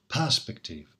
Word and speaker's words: perspective: Firstly, perspective, as you may have noticed, is stressed on the first syllable, perspective, for prosody, so that the line scans properly.